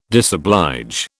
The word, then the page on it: disoblige: British English